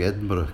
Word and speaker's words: Edinburgh: It's nice to hear you pronouncing Edinburgh correctly. – I had a teacher in middle school who was from Edinburgh, so I learned how to say it even with a bit of a brogue as well.